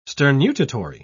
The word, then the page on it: sternutatory: British English